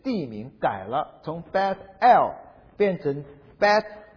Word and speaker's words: Beth El: 那为什么把它改名叫做伯亚文呢？因为伯特利这个字哈，它的希伯来语的念法是 Beth El， Beth B-E-T-H，这个意思是家， Beth El， El 是神的意思，阿拉伯人就念成阿拉，阿拉伯语跟希伯来语是一种同一个语言体系的，所以 El 跟 Allah 是同一个字，所以 Beth El 是神的家。